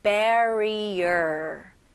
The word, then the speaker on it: barrier: 끝까지 R 발음 마무리 잘 해주셔야 됩니다. 끝부분에 R가 있으면 항상 힘을 주시면서 마무리를 해야 돼요. 그래서 barrier가 아니고요. barrier. barrier. 혀가 안쪽으로 들어가는 느낌이에요.